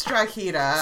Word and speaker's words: Drogheda: I think it's Drogheda. – Sounds good. – I read something on Wikipedia that said something about how they pronounce it in the movie was wrong, and there's two acceptable pronunciations. I don't remember what any of them are, but I believe the G is supposed to be silent.